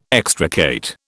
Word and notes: extricate: British English

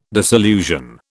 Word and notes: disillusion: British English

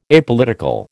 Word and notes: Apolitical: American English

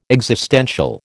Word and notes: existential: American English

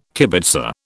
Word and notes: kibitzer: British English

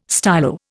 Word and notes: stylo: Indian

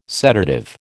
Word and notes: sedative: American English

